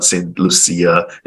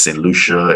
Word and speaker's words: Saint Lucia: Like I keep saying, it's not Saint Lucia, it's Saint Lucia, it's Grenada, uh, and, and, you know it's Dominica, so they they can't find it.